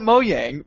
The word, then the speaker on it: Mojang: I'm calling it Mojang because that is how you pronounce J's in Swedish.